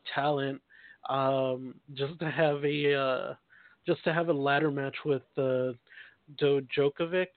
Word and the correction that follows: Dijakovic: Did I say his name right? – Dominic Dijakovic.